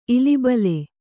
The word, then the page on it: ಇಲಿಬಲೆ: ilibale